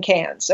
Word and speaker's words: Cairns: That's C-A-I-R-N-S, which we pronounce Cairns, but nobody in Australia says it that way. Say Cairns. Okay, nobody. – Cairns, like C-A-N-S.